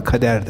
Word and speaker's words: kadar: Bakın kadar, kader. Aynı kelimedir. Biz kadar diye telaffuz ediyoruz, Araplar ona kader derler.